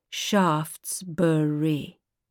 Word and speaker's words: Shaftesbury: No, Shaftesbury, three syllables.